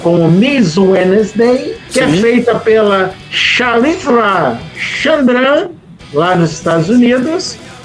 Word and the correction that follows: Charitra Chandran: Ou melhor dizendo, desculpa, eu falei errado o nome dela, é Charitra Chandran, lá dos Estados Unidos.